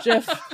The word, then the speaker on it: GIF: It is a soft G pronounced GIF in the story.